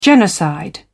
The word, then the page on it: genocide: British English